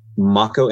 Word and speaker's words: Mako: So my name is spelled Mako, M-A-K-O, Nagasawa, N-A-G-A-S-A-W-A.